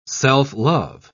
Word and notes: self-love: British English

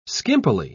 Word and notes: skimpily: British English

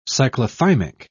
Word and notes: cyclothymic: British English

